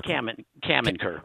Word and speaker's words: Caminker: Caminker.